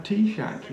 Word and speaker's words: Taoiseach: Which I think is pronounced like Taoiseach, you see.